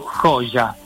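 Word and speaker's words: Joya: questo termine si pronuncia innanzitutto Coja.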